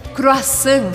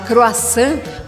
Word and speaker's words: croissant: Você provavelmente adora o croissant francês, mas você sabia que não se fala croissant, mas croissant.